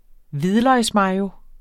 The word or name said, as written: mayo